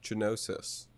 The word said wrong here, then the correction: Gnosis: but... – Well, I'm glad we did this podcast before it comes out so we can get that straightened out. – How was I supposed to know that the G was silent, you know?